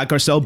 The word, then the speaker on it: Garcelle: Yeah, Garcelle Bouvet.